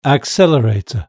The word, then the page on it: accelerator: British English